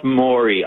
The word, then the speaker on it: Maury: It's pronounced Maury Island. – Oh, Maury Island. That, you're right. Thank you for the correction.